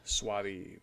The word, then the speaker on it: Soavi: Soavi? Soavi? – Sure. Soavi. Or as yeah. Michael Soavi. Soavi. S-O-A-V-I.